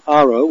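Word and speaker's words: Haro: 8 years ago, we visited Bodegas Roda in the town of Aro, with an H on the beginning that's not pronounced, and we came back now to get an update on the story.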